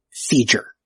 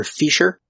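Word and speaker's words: fissure: She pronounces it Feeger. I think she's going for Fisher, but she says it's sort of like a J, so it's even worse, but it's wrong. Either way, it's a Fisher.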